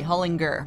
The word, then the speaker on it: Hullinger: Hard G, Hullinger.